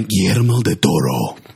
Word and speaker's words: Guillermo del Toro: Directed by Guillermo del Toro.